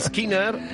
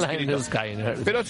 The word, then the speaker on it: Skynyrd: se veía venir, porque en este álbum que escuchamos, que oh, se titula Pronunciet Lynyrd Skynyrd, así separadas las sílabas para que quede claro claro, porque no era una expresión que significara nada para el gran público.